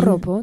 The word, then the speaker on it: Joropo: Et ben écoutons tout de suite je suppose que ça se prononce Joropo de Judeline. – Joropo, tout à fait.